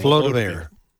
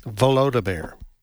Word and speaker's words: Volodymyr: Volodymyr. – Huh? – Volodymyr.